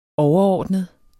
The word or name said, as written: overordnet